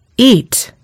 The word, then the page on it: eat: American English